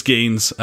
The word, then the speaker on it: Geens: If you're Belgium, it's Greens without the r. – It's Geens.